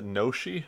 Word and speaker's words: gnocchi: G? G-N-O-C-C? Gnocchi? Is it Gnocchi? – Yeah.